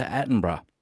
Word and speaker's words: Attenborough: Just a polite reminder, David Attenborough is pronounced as three syllables.